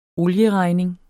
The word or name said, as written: olieregning